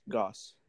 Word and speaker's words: Gauss: And I think it's Gauss. It's pronounced Gauss by the Canadians who made the game.